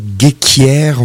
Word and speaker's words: Ghekiere: Des nouveautés, donc l'album de l'année dernière de Abel Gheikhiere, on va le prononcer comme ça, ça s'écrit G-H-E-K-I-E-R-E.